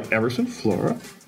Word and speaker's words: Emyrson Flora: Well, I'm joined by Emyrson Flora.